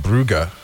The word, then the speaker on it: Brugge: It's Club Brugge.